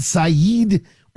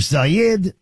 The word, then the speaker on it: Sayed: Beyond what he had to say, although he took straight on the fight in Michigan between Mike Rogers and Abdul El Sayed, or Sayed. I'm now hearing people say it Sayed, whichever. I don't really care. But I do try to get it right. I think either pronunciation is probably, a legally acceptable pronunciation of the name.